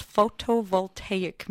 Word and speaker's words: photovoltaic: Okay, so first of all, what in the world does photovoltaic mean? And am I even saying it right? – Oh, okay. So, yeah, you are saying it absolutely yes.